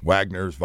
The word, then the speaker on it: Wagners: Wagners, Wagners, whatever the hell they are. But they hustle, man. – The W is pronounced as a V I got to tell you, they play hard.